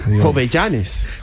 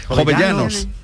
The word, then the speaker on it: Jovellanos: Jovellanes. – Jovellanos.